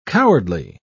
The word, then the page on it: cowardly: British English